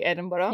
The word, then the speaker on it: Edinburgh: In case anyone's wondering, that's the correct way to say Edinburgh.